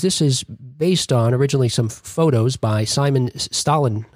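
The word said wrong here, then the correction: Stålenhag: I'm going to say his name wrong, I know. – Huge fan. – You can help me, Robin. Stalinag? – Yeah, Stalinag. And his work,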